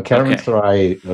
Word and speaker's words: Caravanserai: So Caravanserai? – Yes. So Caravanserai